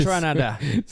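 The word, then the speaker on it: Kaytranada: Juste avant ça, on a entendu Pablo Meza, avec Joroka puis Kate Ranada, si je, si je le, prononce comme du monde. C'est parce que. Je pourrais même pas t'aider, tu sais. – Kate Ranada. Tranada. – C'est ouais, c'est Tranada.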